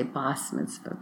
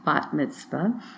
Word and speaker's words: bat mitzvah: I'm using the Israeli pronunciation. Sometimes in some circles they say bas mitzvah, but bat mitzvah.